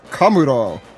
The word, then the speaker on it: Kamuro: This is the manga recap for chapter 927, Otoko the Kamuro. Am I pronouncing that right? – You did it!